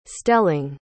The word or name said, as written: stelling